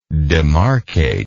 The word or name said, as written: demarcator